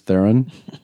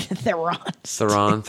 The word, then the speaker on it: Theron: That's not how you say it. – How you say it? Theron? – Theron. – I think she was saying that everybody says it wrong. That everybody says Theron and it's not.